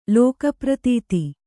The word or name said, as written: ಲೋಕಪ್ರತೀತ